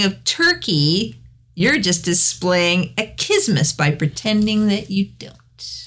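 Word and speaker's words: accismus: It's achismus. It's a Greek word, achismus.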